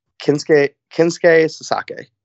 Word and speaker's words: Kensuke Sasaki: How am I pronouncing this? You hit me. – Kensuke Sasaki. – Perfect. Beautiful.